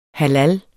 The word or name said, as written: halal